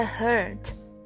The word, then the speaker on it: hurt: From the hurt, hurt, t 可以略读掉。